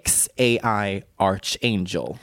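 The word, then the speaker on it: X Æ A-Xii: Jag kan nu härmed stolt meddela att Grimes och Elon Musks barn, alltså XAI-12, uttalas av Grimes som XAI Archangel.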